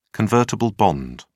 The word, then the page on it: convertible bond: British English